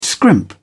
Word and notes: scrimp: British English

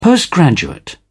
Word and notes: postgraduate: British English

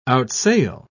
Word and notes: outsail: British English

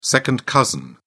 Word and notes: second cousin: British English

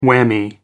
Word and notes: whammy: American English

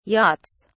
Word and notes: yachts: British English

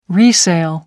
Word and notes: resale: American English

